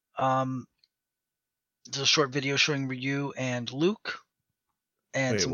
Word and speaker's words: Ryu: The way it's pronounced in Japanese is just yeah. – Ryu.